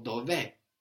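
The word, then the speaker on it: dov'è: So dove, with the accent on the end, and the open E, all by itself, means where is it, where is he, where is she?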